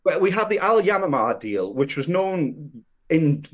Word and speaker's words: Al Yamamah: The Amamaha deal. Am I pronouncing that correctly? It's called It's called the Al Yamamah deal. It's, I believe, Arabic for dove. The Al Yamamah.